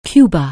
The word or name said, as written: Cuba